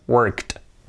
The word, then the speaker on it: worked: Como verbo, pues un verbo regular, work, en pasado worked, pronunciación worked. Ponemos la T al final, sonido T al final, worked.